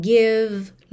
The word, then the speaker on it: give: Também as letrinhas E pintadas de cinza que você não vai pronunciar, tá bem? Give, like, some, all right?